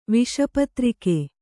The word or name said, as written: ವಿಷಪತ್ರಿಕೆ